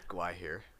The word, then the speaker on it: Gwaihir: Gwaihir. – Gwaihir.